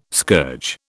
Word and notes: scourge: British English

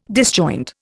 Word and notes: disjoint: Indian

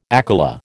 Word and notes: akela: American English

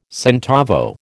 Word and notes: centavo: American English

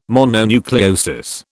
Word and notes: mononucleosis: British English

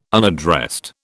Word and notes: unaddressed: British English

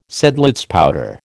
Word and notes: Seidlitz powder: American English

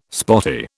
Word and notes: spotty: British English

